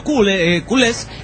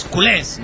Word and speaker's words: culés: Bueno, pirata, te voy a despejar esa duda que tienes de por qué a los del Barça se nos llama culés. – Ah, dice, perfecto. – Con acento en la E. Yo había dicho culés y es culés, ¿no? – Culés.